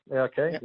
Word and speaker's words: AIK: And then back home in 2007, went back to AIK. I'm pronouncing that right. – Yeah, AIK. Yeah.